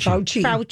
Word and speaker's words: Fauci: She was shout out to Dr. How do you say his Fushi? – Dr. Fauci.